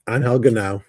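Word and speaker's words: Genao: The Cardinals, they called up, Hanoa, Guardians. – Guardian, sorry, Angel Ganoa, right? – Angel Ganoa, yep. – Ganoa, god damn, so close.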